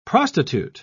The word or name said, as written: prostitute